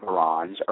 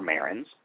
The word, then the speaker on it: Marans: and you may pronounce it Marans or Marans, and scientists claimed that their eggshells were much thicker than the average cell, and so that Salmonella can't get in the eggs.